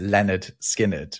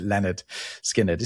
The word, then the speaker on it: Lynyrd Skynyrd: I think you are actually supposed to pronounce it Leonard Skynyrd.